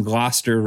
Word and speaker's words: Gloucester: And thanks for pronouncing Gloucester right. That's great. You hear a lot of Gloucesters and things like that, but Gloucester is great.